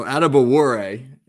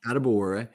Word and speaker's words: Adebawore: Are we even close with the pronunciation? – So Adebawore, not bad at all. Adebawore. Well, he's an interesting guy.